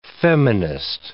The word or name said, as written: feminist